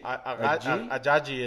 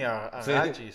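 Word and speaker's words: Ajayi: mi Oye, iba a agarrar, estaba mucho entre Melvin Gordon y Jay, no sé cómo chingado se pronuncia, Jay. – Ajaji, el de Miami, Ajaji, sí, sí, sí.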